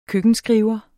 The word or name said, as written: køkkenskriver